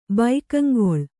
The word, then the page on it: ಭಾಗಂಗೊಳು: bhāgangoḷu